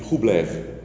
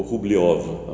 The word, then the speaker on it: Rublev: muitos séculos atrás e se chama a Trindade de Rublev ou Rubliov, a pronúncia do um russo